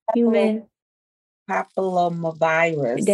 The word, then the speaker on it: human papillomavirus: Human papillomavirus.